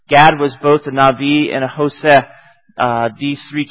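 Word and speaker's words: Navi (נביא): A seer is a really synonym of the word Navi, spelled Nabi, but you pronounce it, I guess, in modern Hebrew, Navi.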